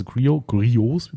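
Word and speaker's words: Griot's: Also Griots wird es übrigens ausgesprochen. – Griots. – Griots, ja. – Also, das Geile ist ja. Stumme T.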